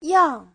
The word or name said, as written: yang4